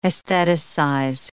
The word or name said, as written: aestheticize